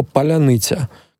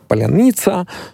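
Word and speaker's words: паляниця: И поэтому человеку, который не учил украинский язык, например, с детства, сложно с первого раза произнести вот так широко, мягко слово «поляныця». Как произношу его я? Как правило, его произносят так: поляница, как-то редуцируя гласные, что тоже характерно для русского языка.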